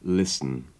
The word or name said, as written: listen